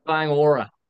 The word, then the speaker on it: Kāinga Ora: looking into, I can't pronounce it, Kanga Aura. – Kayang Aura. – What is it? – Kayang Aura. Yeah.